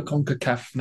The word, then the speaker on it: CONCACAF: Is CONCACAF.